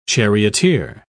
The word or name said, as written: charioteer